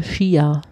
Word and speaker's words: Ski: Die Aussprache ist vor allem wie Ski, also mit SCH. Wie auch original im okay. Norwegischen.